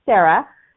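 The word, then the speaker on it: Sara: Um, so welcome Sarah.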